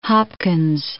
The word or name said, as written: Hopkins